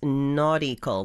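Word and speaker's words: Naughtical: It's called Nautical Glimpses, and I'm not mispronouncing that. It's not nautical like the sea. It's nautical, like naughty, naughty children.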